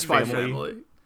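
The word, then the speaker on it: SPY×FAMILY: So I never know how to pronounce the X in names with X's here. So is this just Spy, just Spy Family? – Right.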